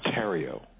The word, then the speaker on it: Theriau: It's pronounced Theriau. – Theriau. – So, like... Yeah, I tell people, it's like Ontario without the on, and that kind of helps.